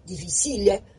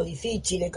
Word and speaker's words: difficile: que es el Clostridium difficile, o difficile, como lo dicen algunos, depende cómo uno lo quiera pronunciar, y